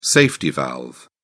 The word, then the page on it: safety valve: British English